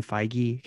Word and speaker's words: Feige: Somebody did ask, is his name Feige? Kevin Feige? Feige? – Feed, just feed. – Is that how you say it? – Yeah.